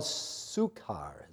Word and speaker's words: Sychar: So he came to a town of Samaria called Sychar, is pronounced correctly, by the way. I looked it up.